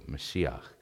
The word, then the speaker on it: Mashiach: That's how you say it in Hebrew, Mashiach.